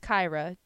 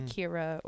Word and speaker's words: Kyra: But my sister's name is Kyra, to pronounce it correctly, not Kyra, or, you know, people mispronounce her name all the time.